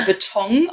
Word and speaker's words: Beton: Zum Beispiel Restaurant, also mit NG hinten. Oder Ja. – Beton, auch mit NG hinten.